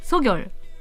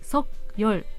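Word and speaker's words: ソ ギ ョ ル (석열): で最近報道でもですね読み方について取り上げられていたんですが、えー、国立国語院によりますと正しい発音はソギョルなんですってソギョル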